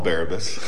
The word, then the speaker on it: Barabbas: This man, you can call him Barabbas. That's a fine pronunciation. I in my head, it's Barabbas. So however you want to roll. That's how I roll Barabbas. I'm not throwing my wife under the bus. I say Barabbas.